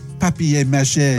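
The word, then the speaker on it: papier-mâché: Paper mâché. – Can you say it properly, please? – Papier mâché. – That's better.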